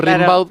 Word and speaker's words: Rimbaud: Claro, como se escribe, se pronuncia Rimbaud, pero es Rimbaud.